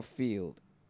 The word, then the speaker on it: Feeld: I think it's called Field. It's spelled F-E-E-L-D. – F-E-L. – Two E's. – Yeah. – Oh, I was thinking like feel like. – Like play the field.